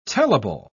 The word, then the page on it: tellable: British English